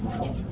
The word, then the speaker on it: SMIL: Okay, so SMILE, which is written S-M-I-L, the pronounced SMILE, is a standard, and the RealPlayer happens to implement the standard, so you can get that for free.